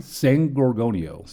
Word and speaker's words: San Gorgonio: San Gorgonio.